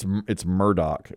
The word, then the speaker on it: Murdaugh: Yeah, it's Murdaugh.